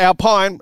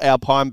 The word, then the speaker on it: Alpine: Not Alpine. It's spelt Alpine, but it's an Alpine A110.